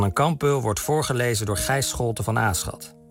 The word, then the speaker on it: Gijs Scholte van Aschat: Verder horen we dus inderdaad Gijs Scholte van Aschat. Zo spreek je dat blijkbaar uit.